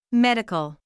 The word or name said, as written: medical